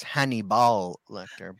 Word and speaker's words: Hannibal Lecter: Buffalo Bill and Hannibal Lecter. – It's pronounced Hannibal Lecter, but yes.